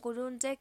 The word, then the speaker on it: Cullunche: Y si no, también pueden donar, ayudando a donar cualquier monto, suma, todo sirve, al alias de la Fundación Cuyunche, que es fundación.cuyunche. Cuyunche se escribe Cullunche.